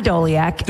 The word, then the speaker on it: Doleac: Spell his name, pronounce his name. – Doleac. Yeah, Adam Doleac. – Okay, we have Adam Doleac, yeah.